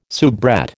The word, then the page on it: soubrette: American English